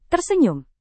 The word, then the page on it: tersenyum: Indonesian